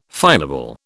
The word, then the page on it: finable: British English